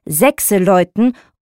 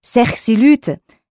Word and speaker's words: Sechseläuten: Deswegen heißt es Sechseläuten oder Sechsilüte, wie es in unserem Dialekt ausgesprochen wird.